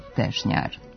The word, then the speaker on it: Tešnjar: Nekad čaršija iz doba Turaka ime je dobila ili po nekom turskom begu ili po toj stešnjenosti, a izgovara se sa kratkouzlaznim akcentom na prvom i dužinom na sljedećem slogu. U promjeni, kratkouzlazni akcenat sa prvog sloga prelazi u dugouzlazni na drugom, a dužina ostaje na kraju, pa dobijamo oblik Tešnjaru.